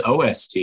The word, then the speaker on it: OST: And because we immediately get to the topics that matter, I had always abbreviated out-of-school time as OOST and pronounced it in my head, OOST. But in email setting up this interview, it was OST, and I Googled it, and it seems like Montgomery County calls it OOST, but DC calls it OST.